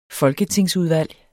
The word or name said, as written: folketingsudvalg